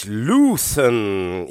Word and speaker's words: LVTHN: das eben gerade gehörte, kommt aus Belgien und die Band nennt sich Luthen. Ich gehe davon aus, dass es zumindest so ausgesprochen wird. LVTHN geschrieben.